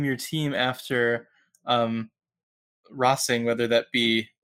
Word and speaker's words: Racing: The fact that NWSL had the big announcement this morning that Louisville will be what they would want us to call Racing Louisville, but clearly has to be pronounced Rossing Louisville. if they're going to take a soccer tradition from another country, they're stuck with the Spanish pronunciation, I think.